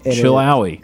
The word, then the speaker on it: Chilhowie: Maybe you'll head over to Chill Howie. – Excuse me? – Chill Howie. – Chill Howie. – C-H-I-L-H-O-W-I-E. Chill Howie. Chill Howie.